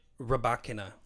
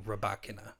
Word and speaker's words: Rybakina: Rybakina. All of the commentators on NBC, Mary Carrillo and John McEnroe, I hope you're pronouncing it correct, because you have been saying Rybakina. – Yes, we're following your suit.